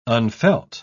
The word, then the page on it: unfelt: British English